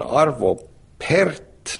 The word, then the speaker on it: Arvo Pärt: Ja siis pärast tuli teadmuste ütles, et jah, see on uus plaat, on, siin on mingi inimene, ta nimi on Arvo Pärt, no hääldas siis äätähte saksa moodi, ja ta on eestlane.